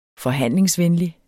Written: forhandlingsvenlig

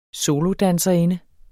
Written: solodanserinde

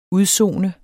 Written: UDSONEDES

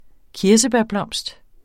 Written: kirsebærblomst

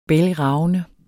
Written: bælgravende

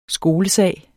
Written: skolesag

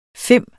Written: fim

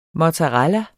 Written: mozzarella

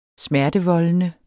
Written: smertevoldende

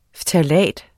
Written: ftalat

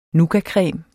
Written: nougatcreme

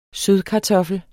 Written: sødkartoffel

